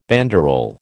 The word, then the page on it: banderole: American English